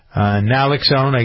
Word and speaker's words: naloxone: The company has a lot of drugs, Advil, they make opioid drug overdose reverser, Naxil, am I saying that right? Naloxone, I guess it is, N-A-L-O-X-O-N-E, anyway.